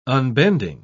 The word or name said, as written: unbending